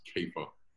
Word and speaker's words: Kepha: Cephas is the Aramaic, uh, in Aramaic I think it would be pronounced Kepha, uh, and it's the Aramaic word for rock.